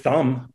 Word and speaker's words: thumb: Well, let's go with thumb because, I mean, thumb. – Thumb. – Yep. – Thumb. Another noun. – Yeah, with the silent B.